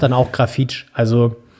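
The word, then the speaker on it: Grafite: Ja, es gibt, du kannst beides irgendwie, also er selber sagt wohl eher Grafit oder Grafit.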